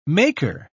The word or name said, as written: maker